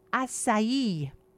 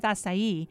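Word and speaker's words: açaí: So that's going to be your blueberries, your purple grapes, plums, beets, red cabbage, and also acai. Acai. I was told by Rita that you put the emphasis on the I. It's not acai, it's acai, and it's not a K.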